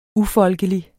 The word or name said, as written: ufolkelig